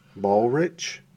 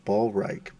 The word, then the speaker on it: Ballreich: I don't even know how to say it because I've heard it pronounced a thousand ways. Ballrich, Ballreich. Or some people say Ballreich, but then I feel like I'm, Definitely Reich.